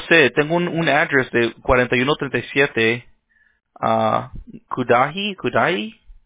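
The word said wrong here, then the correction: Cudahy: Street. No sé si es... – Es Karahei Street, ajá. – Karahei, okay. No sé cómo, no sabía cómo pronunciarlo, okay.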